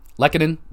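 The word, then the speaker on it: Lehkonen: Did I say that right? Lekkonen, there you go.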